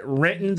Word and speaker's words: Renton: You got to say it right. Renton. There's no T in the word Renton. We say it without the T.